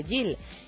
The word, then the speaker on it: Gil: dijera referente a Ariadna Gil que a esta le gusta que su apellido se pronuncie Gil. Y bueno, quiere comentar que la explicación no es otra que en catalán es así como se pronuncia, no es que Ariadna sea una excéntrica.